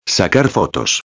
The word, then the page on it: sacar fotos: espanhol